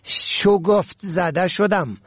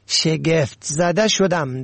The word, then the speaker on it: شگفت: این دو کلمه و واژه را در گذشته هم باری تذکر دادم، اما بسیاری به سبب هم شکل بودن آن بار بار نادرست تلفظ می کنن. مثلا می گوین شگفت زده شدم. غلط است، باید بگویید شگفت زده شدم، در حالی که هر کدام جایگاه و معنی خاص خود را دارد.